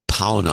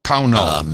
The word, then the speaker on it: Pownal: Pownall, I don't know how to pronounce it. P-O-W-N-A-L. – Pownall. Pownall.